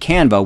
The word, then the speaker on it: Canva: And then he's like, yeah, the Zoom backgrounds in Canva would be great with this software. And yes, that's how you pronounce it.